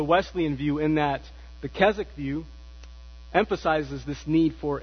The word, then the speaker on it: Keswick: So the W is silent. I didn't know that until I read that. I was pronouncing it Keswick, but it's actually Keswick.